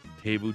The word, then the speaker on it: Teuvo: Teuvo Teravainen. – Teuvo.